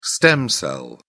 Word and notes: stem cell: British English

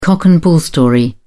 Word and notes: cock-and-bull story: British English

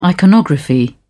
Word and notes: iconography: British English

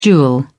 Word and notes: jewel: British English

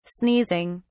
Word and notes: sneezing: British English

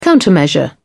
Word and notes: countermeasure: British English

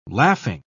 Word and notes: laughing: British English

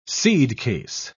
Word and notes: seedcase: British English

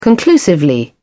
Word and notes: conclusively: British English

